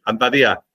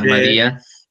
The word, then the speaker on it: Ahmadiyya: Lo pronuncié bien, Ahmadiyya. – Sí, exactamente, pronunciaste muy bien, la comunidad Ahmadía.